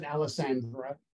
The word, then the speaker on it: Alessandra: Yeah, believe me, I've heard every imaginable pronunciation. You know, Alessalamander, and you know, you name it. So, I pronounce it Alessandra, and that's it.